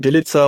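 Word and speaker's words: Bjelica: Wir nennen ihn Bilica und ernten damit zumindest keinen Widerspruch bisher. Also Bilica ist das, wie wir angesprochen wird von uns.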